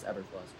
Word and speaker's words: Eberflus: I thought his last name was hard to pronounce, but it's Eberflus.